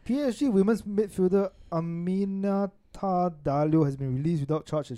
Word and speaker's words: Diallo: A-M-I-N-A-T-E space D-A-L-I-O, if I'm not wrong. Or D-A-L-L-I-O. – Aminate Diablo. – Diallo. – Diallo, Diallo. D-I-A-L-L-O.